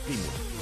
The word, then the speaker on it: Plymouth: Es a las 7 menos cuarto contra un equipo de segunda división, Manchester City, Plymouth o Plymouth. Me parece que es Plymouth, como pronuncian ellos.